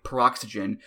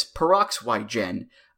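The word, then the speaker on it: Peroxwhy?gen: And I guess you're supposed to say the word Y like it's the letter Y, Paroxygen, which is a very Jeff Hardy thing to do. Now, 11 year old did not know that, so he pronounced it as Peroxygen.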